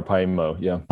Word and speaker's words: Paimio: Yeah, or Paimio, Paimio, and that was in Finland, is that correct? – That's right, it's in Finland, yeah.